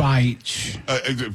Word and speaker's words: Beich: Because we were trying to pronounce, I was trying to pronounce a reporter's name, and it's spelled like, bitch. Something like, it's like that. – But it's pronounced exactly that. – Beich. – From what we understand, right, it's pronounced Beich.